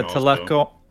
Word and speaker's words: Talokan: Talokan? Is that how you pronounce their land? – Talokan? – I think you said it right.